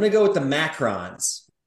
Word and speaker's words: macaron: And there's one I think I always pronounce it wrong, but I'm going to take the one spelled M-A-C-A-R-O-N, not the O-O-N. It's pronounced macarons, and they are little expensive, almost like sandwich-like cookies that are different flavors.